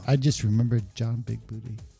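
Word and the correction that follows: Bigboote: Boutte. – Sorry, yes. – I mispronounced that.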